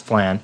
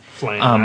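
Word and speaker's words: Phlan: Okay, the central plot of the game is that New Flan, P-H-L-A-N, I think it's pronounced Flan. – Flan, that's how it always pronounced.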